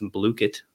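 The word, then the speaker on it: Blooket: And my favorite is Blookit, actually pronounced Blookit. I just don't like to say it that way.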